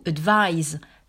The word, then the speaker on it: advise: Faites attention d'ailleurs de ne pas le confondre avec to advise, avec le S qui se prononce d'ailleurs avec un son Z lorsqu'il s'agit du verbe conseiller.